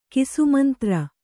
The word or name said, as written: ಕಿಸುಮಂತ್ರ